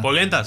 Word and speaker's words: polenta: ¿Pulenta cómo se dice? – ¿Eh? – Pulenta, ¿verdad? – Polenta, sí, polenta, Pulenta. – polenta, muy rico ese.